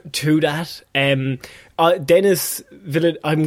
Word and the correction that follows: Denis Villeneuve: Please, it's uh, Denis Villeneuve. – Denis Villeneuve.